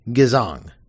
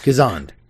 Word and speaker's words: Gezond: Gezang. Gezond.